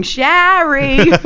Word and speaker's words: Shari: But I always say, as soon as I say, just picture a Jewish mother going, Shari! Then you know how to spell it.